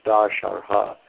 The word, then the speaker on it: Dasharha: The dynasty of Dashara with a long A, Dasharha. Excuse me, I'm saying it wrong. It's Dasharha. So with a long A,